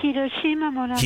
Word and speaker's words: Hiroshima Mon Amour: Hiroshima Mon Amour.